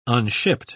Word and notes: unshipped: British English

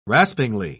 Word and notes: raspingly: British English

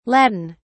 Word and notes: Latin: British English

